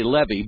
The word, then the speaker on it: Levy: And it's spelled L-E-V-Y. You would say Levy, but everybody from that area, if you know that area, you know I'm saying it right, Levy.